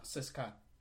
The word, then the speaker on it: CSKA: I've heard people say Seska. – Seska. Yeah. – Okay. That's how you say it.